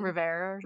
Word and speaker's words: Rivera: Rivera.